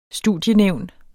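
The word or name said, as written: STUDIENÆVN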